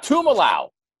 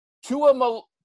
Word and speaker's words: Tuimoloau: It's not Tuimoloau, which I've said 1,000 times. Tua Malolau. Tua Malolau. We have. There's a whole. – You just have to pronounce every. You just have to pronounce every vowel, basically.